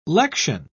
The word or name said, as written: lection